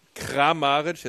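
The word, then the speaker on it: Kramarić: manchmal nehmen wir den Karamitsch vorne, nee, Kamaric vorne. Habe ich das richtig gesagt? – Ja. Ne? Kramaric. – Kramaric, jetzt habe ich es, siehst du.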